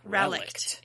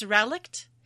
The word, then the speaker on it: relict: Looks like relict. – It's relict, and it is a thing that, which has survived from an earlier period or in a primitive form.